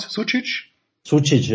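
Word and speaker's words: Sučić: Und wenn ich den kroatischen Kader mir jetzt anschaue, das ist natürlich sehr, sehr interessant, dass zum Beispiel jemand wie Luka Sucic oder wie spricht man ihn aus? Sucic, ja, genau.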